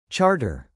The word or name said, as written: charter